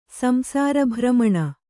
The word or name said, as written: ಸಂಸಾರಭ್ರಮಣ